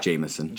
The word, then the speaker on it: Jameson: Yeah, you'd pronounce it like as if you were actually reading it, I guess. But yeah, it's just Jameson.